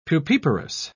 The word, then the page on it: pupiparous: British English